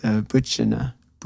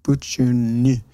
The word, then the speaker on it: Bylgjuni: I always say this name wrong, but Butchina. Butchuni. B-Y-L-G-J-U-N-I.